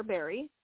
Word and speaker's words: Barry: Barry, all one word.